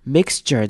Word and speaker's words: mixture: Mixture 这个字要特别注意的是 x 跟 ture 的念法，这边中间我们会念成 mixture。有没有发现是念成 x 完之后念 j 的声音，像是 j 或者是 g 的声音，所以是 mixture。